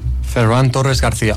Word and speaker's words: Ferran Torres García: Ferran Torres García.